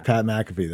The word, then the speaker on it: Pat McAfee: Pat McAfee.